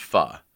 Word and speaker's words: Pho: It's called now, I know it's pronounced Pho, right? But I feel really pretentious. I think we've discussed this on the show. I feel very pretentious saying that. So I'm going to say it's called Pho Na Trang.